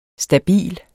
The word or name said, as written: stabil